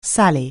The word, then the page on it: sally: anglais